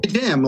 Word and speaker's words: Kajam: Did I say that right, Kajam? I think I did, right? – It's cool. Kajam, like Shazam.